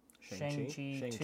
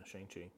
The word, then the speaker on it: Shang-Chi: Chang, gonna butcher that. – Shang-Chi 2. Yeah, Shang-Chi.